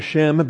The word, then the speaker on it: Shem: In fact, we pronounce it Shem because we don't want to pronounce it shame.